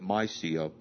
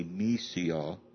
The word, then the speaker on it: Mysia: After they had come to Mysia, I would have pronounced it Mysia, but I'm told it's more properly Mysia.